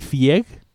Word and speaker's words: Fieg: Eh, Ronnie Fike que se escribe se escribe F y E G, que se pronunciaría Ronnie Fieg.